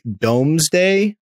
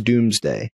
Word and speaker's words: Domesday: But it's like Domesday, but I think it's pronounced Doomsday.